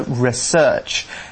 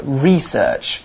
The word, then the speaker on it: research: I've been saying research the whole time, and that's actually the American way of saying the word. In the US, they put the emphasis on the search part, so research. However, if you go to the UK, you'll notice that normally we say research. We put the emphasis on the E, so research.